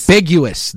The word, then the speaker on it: ambiguous: Now, what the biggest issue is in my mind in terms of taxpayer dollars going towards the NFL in some ambiguity or ambiguous way. I don't even think I said that right. – Ambiguous. – That is what I was looking for.